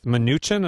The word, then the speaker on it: Mnuchin: Talk about Seth Mnuchin.